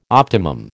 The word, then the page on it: optimum: American English